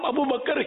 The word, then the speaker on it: ابو بکر: بکر بکر ابو بکر, عوام ابو بکر ہی بولتے ہیں, صحیح لفظ تلفظ ہے ابو بکر, ابو بکر.